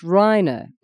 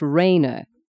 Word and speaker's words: Rainer: I've told you before, my name is pronounced Reiner, not Rainer."